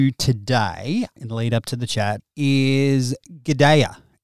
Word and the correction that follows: Gaida: Is that how you say it? – Her name is Gaida.